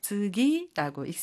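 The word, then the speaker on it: 즈기 (次): 버금차라고 하는 한자를 사용해서 즈기라고 읽습니다.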